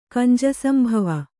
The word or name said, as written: ಕಂಜಸಂಭವ